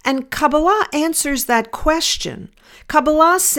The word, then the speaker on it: Kabbalah: In Kabbalah, it's taught, Kabbalah is the correct Hebrew pronunciation.